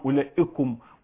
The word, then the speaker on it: أولئكم: فيبقى مكتوبة عندك وأولئكم هكذا واو ألف واو، بس الواو ديت لا تنطق، فأربع كلمات خاصة، أولو، أولي، أولاتو هكذا، أولئك، أولئك، وتبقى معهم أولئكم وما إلى ذلك زي ما وضح.